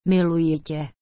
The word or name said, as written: miluji te